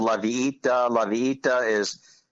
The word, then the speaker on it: La Villita: So anyway, that's how you say it, La Villita. It's a Spanish word.